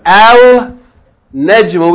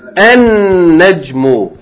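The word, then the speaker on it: En Nejmu (النجم): So instead of saying Al Nejmu, we're going to say what? En Nejmu.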